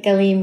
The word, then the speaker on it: Galim: Did I say it right? – Uh-huh, you said it right. Galim means waves in Hebrew.